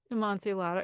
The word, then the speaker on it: Amontillado: Amontillado.